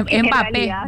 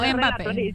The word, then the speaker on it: Mbappé: Es Mbappé, no Mbappé.